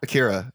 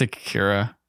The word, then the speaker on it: Akira: Akira.